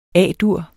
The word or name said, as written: A-dur